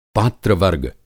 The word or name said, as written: पात्र-वर्ग